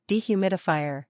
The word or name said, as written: Dehumidifier